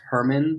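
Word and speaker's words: Germán: His name is actually pronounced Herman, which nobody knows except for us and our editor, Darren. Yeah, everyone always says he's a Spanish guy.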